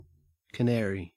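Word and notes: canary: au Australian English

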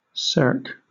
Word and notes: cirque: British English

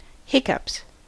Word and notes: hiccups: American English

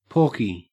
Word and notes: porky: Australian English

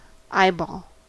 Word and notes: eyeball: American English

